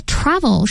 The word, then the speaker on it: travel: 但这个 travel 实际上它的重音是在第一个音节、啊